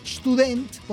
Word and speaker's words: Student: Por ejemplo, la palabra estudiante, que en alemán es Student, debería ser Student, según la pronunciación alemana. – Sí. – Pero no, es Student por influencia del francés.